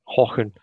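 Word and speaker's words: Häcken: BK Hacken, is that how you pronounce it? – Yeah, it's BK or FC Hacken. – Hocken.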